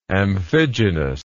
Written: amphigenously

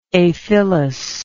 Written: aphylly

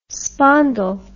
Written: spondyl

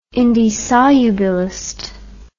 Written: indissolubilist

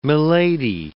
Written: milady

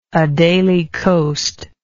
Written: Adelie Coast

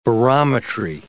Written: barometry

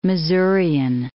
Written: Missourian

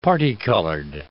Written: parti-colored

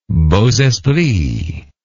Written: beaux esprits

